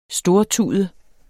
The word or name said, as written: stortude